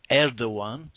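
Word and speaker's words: Erdoğan: But since Recep Tayyip Erdogan, spelt E-R-D-O-G-A-N, but pronounced Erdogan, because it's got a funny cedilla, it's not a cedilla, but it's got a... what you have on España, you know, it has this straight, the G has a weird Turkish accent on top of it, which means it's pronounced W, so it's Erdogan.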